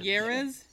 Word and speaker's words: Jerez: Is it Jerez? – Say it with a Y. Jerez.